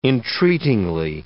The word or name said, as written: entreatingly